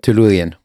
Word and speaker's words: Tellurian: Tellurian.